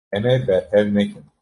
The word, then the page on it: nekin: Kurdish